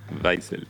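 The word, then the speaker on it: Waizel: Uriel Weisel, mi apellido, bueno, se le pueden preguntar a Alonso, pero es W-A-I latina Z-E-L, Weisel. – Weisel, sí, se pronuncia así.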